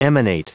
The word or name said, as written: emanate